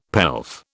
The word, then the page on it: pelf: British English